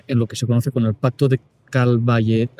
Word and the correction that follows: Caltabellota: A ver si lo digo bien. Pacto de Caltabellota, en 1302.